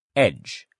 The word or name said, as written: edge